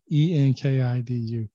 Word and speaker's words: Enkidu: And Enkidu, I think I'm saying that correctly. If I'm not, it's spelled Enkidu. I said that wrong. Enkidu.